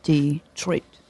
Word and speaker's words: Detroit: Detroit. Soft. Hard. Detroit. It's two syllables with the accent on the second part.